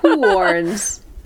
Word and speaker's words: Huorns: I'm gonna just say this the way I think it's said. The Huorns. – The Huorns. – This is spelled H-U-O-R-N-S. Huorns.